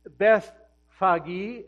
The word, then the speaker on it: Bethphage: near the town of Bethphage, or Bethphage, as some people pronounce it.